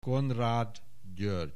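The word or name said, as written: Konrád György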